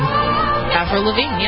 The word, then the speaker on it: Lavigne: Avril Lavigne, yeah.